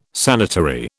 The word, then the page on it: sanitary: British English